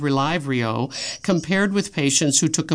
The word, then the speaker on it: Relyvrio: This is spelled R-E-L-Y-V-R-I-O, and I'm going to call it Relyvrio.